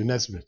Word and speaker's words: Onesimus: I suspect, how many of you know the story of Philemon and Onesimus, how do you say it? The slave. Yeah, thank you, Onesimus.